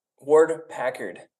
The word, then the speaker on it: Ward-Packard: Ward-Packard.